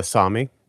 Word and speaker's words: Sami: Up north, is that still like the area of the Sami people? I'm not sure how to pronounce that. – Yeah, the Sami.